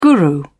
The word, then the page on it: guru: British English